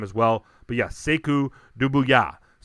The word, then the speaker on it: Doumbouya: So think Stuart Scott with the booyah, emphasis on that last syllable. Go short and quick with booyah, doom boom, and then doom boo, and then go with the yah. So Doumbouya.